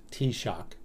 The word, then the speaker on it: Taoiseach: The name of the prime minister, or the title of the prime minister in Ireland in the native language is pronounced Taoiseach.